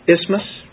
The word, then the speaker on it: isthmus: And uh, I think you're supposed to pronounce it isthmus, right? Isn't that the way to do it? But if you spell it out with that, it sounds like you got a you have a tooth problem or something.